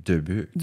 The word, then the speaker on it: Dubuque: Dubuque. – Yeah. Mais vu que c'est fondé par un Français canadien, la prononciation devrait être Dubuque.